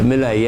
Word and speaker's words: Milei: I'm not going to pronounce his name correctly, Milei. So I won't bother. Milei, yeah. Saying that they want the Falklands back, and Trump slightly siding.